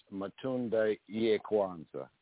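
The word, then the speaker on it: Matunda ya Kwanza: And the right pronunciation is Matunda Ye Kwanzaa.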